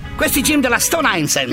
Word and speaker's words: Stone Island: Questi gym della Stone Islands. – Stone Island. – Ma perché scrivono? Scrivono come si legge. – Si chiama così, Stone Island.